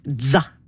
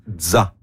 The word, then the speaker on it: ZZA: On l'appelle ZA. Comme la fin de pizza. – ZA.